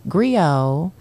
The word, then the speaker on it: griot: Griot has a silent T at the end. It's GRIOT.